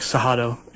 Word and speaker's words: Cerrado: And the region there is called the Cerrado, and it's actually pronounced like Cerrado.